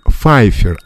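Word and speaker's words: Pfeiffer: А, был фильм с Мишель Файфер, Файфер, а не П. Файфер.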